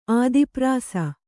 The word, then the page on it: ಆದಿಪ್ರಾಸ: ādiprāsa